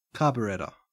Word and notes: carburettor: Australian English

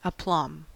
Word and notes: aplomb: American English

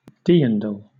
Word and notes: dirndl: British English

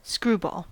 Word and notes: screwball: American English

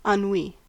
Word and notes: ennui: American English

